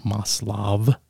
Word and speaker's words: Maslow: Actually Maslow, but he's of Russian descent, so you pronounce the V, Maslov.